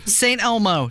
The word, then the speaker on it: St. Elmo: Thank you for saying it right, because I don't want the mean tweets to come in. I can't believe they put an S at the end of it.